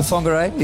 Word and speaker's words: Whangarei: You are from Whangarei. – Yeah, Whangarei. Yeah, right? – Yeah. – That's how you pronounce it? – Yeah.